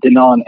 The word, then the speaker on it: Dinan: a lot of people, when they see my name written down, they pronounce it Dinan anyway. So we just figured we'd go with that.